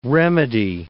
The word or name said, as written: remedy